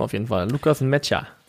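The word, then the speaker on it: Nmecha: Ich glaube, ein Matcher ist richtig, Matcher, auf jeden Fall, ja.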